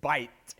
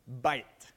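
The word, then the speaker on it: Bite (בית): Bite. Bite. If you slow it down, it sounds like buy it, but they would say it like this, bite.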